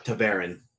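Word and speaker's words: ta'veren: Now, yeah, the, it, the second syllable, excuse me, is, is veer, according once again to the glossary at the end of the actual physical book. Taverin.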